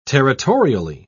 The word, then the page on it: territorially: British English